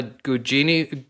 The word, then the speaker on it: Gugino: Um, the film stars uh, Carla Gugino.